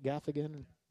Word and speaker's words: Gaffigan: Did I say his name right? Gaffigan? Yeah, okay.